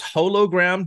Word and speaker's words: hologram: Is it pronounced hologram during Deep Space Nine, and once Voyager starts, it changes to hologram? – No. – Okay. – No, it's hologram. That's how you say it.